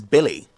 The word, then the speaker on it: Billy: My name is Billy.